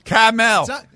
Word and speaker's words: Carmel: It's not even spelled caramel. – Yeah, it is. – No, it's C-A-R-M-E-L. – Caramel. Caramel. – Yes, thank you. – Carmel. – Well, you didn't. – Carmel. – Carmel. – Carmel is how you pronounce the one in California.